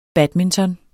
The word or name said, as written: badminton